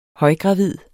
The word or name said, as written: højgravid